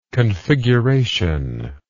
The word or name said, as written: configurationally